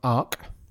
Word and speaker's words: ARQ: Watched Arc. So one of the Netflix exclusives. – Oh, okay. – It's spelled ARQ.